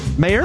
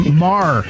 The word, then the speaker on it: Maher: It's Mar. – Mar. Thank you. Thank you. Now I know how to say it so I don't fuck it up again. – He's been correcting you. Basically, it's phonetics, dude. Sound it out. Maher is not it. Mar.